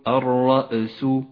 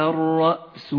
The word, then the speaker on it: الرأس: والهمزة ساكنة لا تحتاج إلى قلقلة، أي لا تقول الرأس فإنه خطأ، صوابه واشتعل الرأس شيبا.